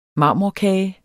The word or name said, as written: marmorkage